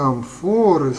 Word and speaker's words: амфоры: А дальше еще амфоры с ударением на первое слово и взоры.